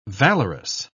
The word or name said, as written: valorous